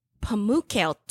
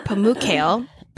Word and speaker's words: Pamukkale: I'm going to Pamukkale, Turkey. – Whoa. – I looked up how to pronounce it. That's what it said. Pamukkale.